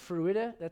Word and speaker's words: Fruita: I think it's Fruita.